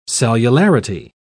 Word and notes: cellularity: British English

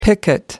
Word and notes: picket: American English

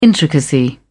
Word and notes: intricacy: British English

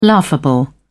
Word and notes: laughable: British English

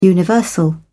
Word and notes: universal: British English

